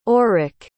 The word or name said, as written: orrick